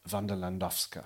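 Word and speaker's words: Wanda Landowska: Yeah, Wanderlandowska.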